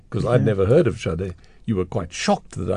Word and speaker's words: Sade: That was called The Sweetest Taboo by, is it pronounced Sade? – Sade, yeah.